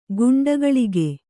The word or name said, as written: ಕುಂಡಗೋಲಕ